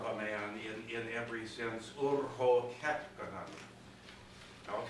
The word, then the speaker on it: Urho Kekkonen: If you just hit the first syllable hard of every word, it's an inflected language. You are speaking flawless Finnish. it'll be that easy for you. Urho Kekkonen.